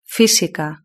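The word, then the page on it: fisica: Spanish (Spain)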